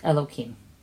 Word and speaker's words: Elokim: but this name of Hashem is supposed to be pronounced Elokim.